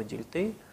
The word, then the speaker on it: Дильтей: И посвящена она будет Вильгельму Дильтео. Ну, я именно с немецким ударением привык произносить его фамилию, хотя часто называют его Дильтей.